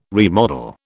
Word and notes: remodel: British English

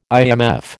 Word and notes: IMF: American English

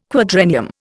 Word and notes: quadrennium: Indian

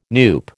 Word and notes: NUPE: American English

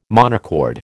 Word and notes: monochord: American English

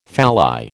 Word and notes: phalli: American English

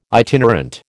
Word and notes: itinerant: American English

